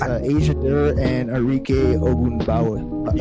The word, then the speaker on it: Arike: Oh, no, it's Arike. A-R-I-K-E. – Yep. – Arike. – Yep, that's exactly how you pronounce that.